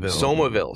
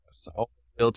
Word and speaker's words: Somerville: So we're just fresh off Destination Defender 23 here in beautiful, I guess it's not Somerville, Texas. What did Joey say last night? He said like Somerville. – Somerville, Texas. – Somerville, Texas. The correct pronunciation.